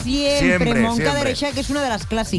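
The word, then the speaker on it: Moncada i Reixac: Bueno, nos vamos a Moncada y Reixac. – Moncada y Reixac, ¿lo he dicho bien? Sí.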